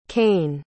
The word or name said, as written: caine